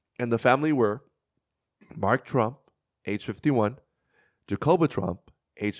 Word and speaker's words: Tromp: Trump. Not Trump. – So it's basically, so it's like spelled like Trump, but instead of the U, it's an O? – Yeah. – Okay, Trump.